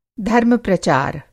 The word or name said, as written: धर्म-प्रचार